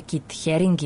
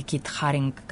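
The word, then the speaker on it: Keith Haring: To je Kit Haring, ili Kit Haring, kako ko izgovara.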